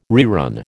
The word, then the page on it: rerun: American English